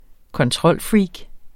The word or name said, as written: kontrolfreak